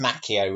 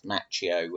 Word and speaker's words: Macchio: But it's pronounced Macchio with him, and it's pronounced Macchio with the actor.